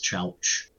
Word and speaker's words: Chelch: With the pronunciation, like, you are getting it right. It is Chelch.